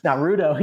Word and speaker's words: Naruto: Yeah, so I knew I said it wrong. I said Naruto. What is it called? Naruto? – Naruto. Yeah, you got in my head. I almost said it wrong too.